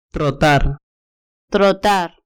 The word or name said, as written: trotar